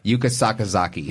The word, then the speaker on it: Yuka Sakazaki: Yuka Sakazaki. That's an easy one. – Right. Yuka Sakazaki? – That's, yeah. Okay. Sakazaki? – Yuka Sakazaki. – Yes. – Okay. Yuka Sakazaki.